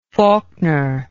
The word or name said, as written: falkner